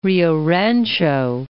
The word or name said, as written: Rio Rancho